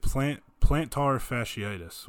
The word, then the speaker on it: plantar fasciitis: I think they meant it plantar fasciitis, which doesn't sound very nice.